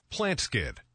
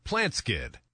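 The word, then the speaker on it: Plant Skydd: Plant Skid. That's spelled plant, S-K-Y-D-D. Plant Skid. – Did you get the spelling on that? It's Plant Skid. P-L-A-N-T-S-K-Y double D.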